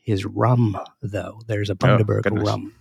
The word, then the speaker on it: Bundaberg: Quick correction on the name of the town. He's from Bundaberg, is pronounced und as in fun. Oh, sorry, und as in fund. Daberg. Bundaberg. As opposed to Bundaberg, which is, I think, how I'd said it.